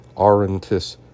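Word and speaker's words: Auranitis: Let me see if I'm pronouncing that one right. That is a T. Aurontus, which encompasses much of what today is modern Israel, the Palestinian territories, northern Jordan, and southwestern Syria.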